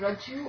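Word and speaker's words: rajju: Is it Raju or Raju? – Raju. – The U, short U. – But just one jo, Raju? – Raju. – Raju. – Raju. – Yeah. – Raju. Double J. Raju.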